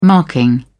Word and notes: marking: British English

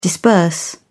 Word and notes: disburse: British English

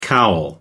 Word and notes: cowl: American English

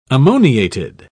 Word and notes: ammoniated: British English

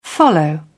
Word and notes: follow through: British English

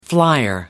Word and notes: flier: American English

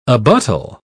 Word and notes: abuttal: British English

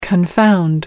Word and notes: confound: American English

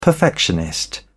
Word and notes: perfectionist: British English

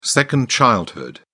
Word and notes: second childhood: British English

